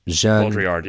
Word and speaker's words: Baudrillard: So in this, in like, you've read, is it Baudrillard? I never know how to say it. Jean Baudrillard. Yeah. – Baudrillard.